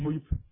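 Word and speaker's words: ホユㇷ゚: ホユッ、でこのプーは小文字ですから、あのホユッというふうに飲み込むように発音します。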